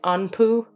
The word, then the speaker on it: Anpu: Okay, so yes, there's a long history of past lives that in the tradition that Anpu is from. Is that how you say it? Anpu? – A-N-P-U. – Okay.